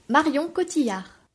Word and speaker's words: Marion Cotillard: The girl that you're thinking about is, I really don't know how to say, pronounce this name because it's French. But in English, it's kind of, I think it's pronounced Marion Cotillard.